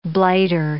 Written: blighter

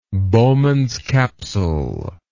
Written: Bowman's capsule

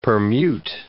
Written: permute

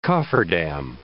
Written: cofferdam

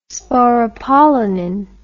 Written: sporopollenin

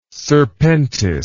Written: serpentis

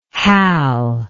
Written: hal

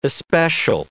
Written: especial

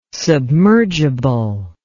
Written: submergible

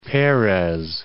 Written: Peres

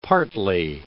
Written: partly

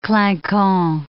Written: clinquant

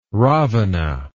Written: ravana

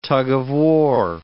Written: tug-of-war

